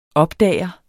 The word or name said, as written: opdager